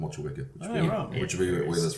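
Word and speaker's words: Motueka: I'm still not pronouncing Matuka right. Matuika. – So in Kiwi terms, it would be Mochiweka, Waiti, and Ruaka.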